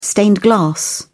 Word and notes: stained glass: British English

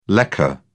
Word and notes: lekker: British English

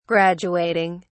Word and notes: graduating: British English

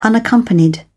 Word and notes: unaccompanied: British English

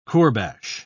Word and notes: kourbash: British English

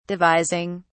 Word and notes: devising: British English

